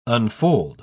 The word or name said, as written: unfold